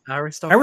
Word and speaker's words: aristocracy: Aristocracy. No. Aristocracy. – Arist- aristocracy. Aristocracy is right. Yes. The the accent is on the O.